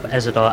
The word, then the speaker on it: Ezador: E Z A D O R.